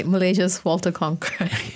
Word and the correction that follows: Cronkite: Walter Cronkite.